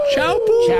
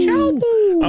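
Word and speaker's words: Teahupo'o: Believe it's pronounced Chaupu. – Chaupu.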